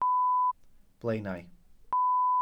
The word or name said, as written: blaenau